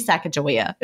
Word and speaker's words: Sacagawea: That's the way we pronounced it. I grew up in Virginia and I went to Camp Sacagawea, which was a Girl Scout camp. So that's how we said it. But I think other people pronounce it differently. So if I'm saying it wrong, apologies. – Yeah, the way you're saying it is how I've always known it.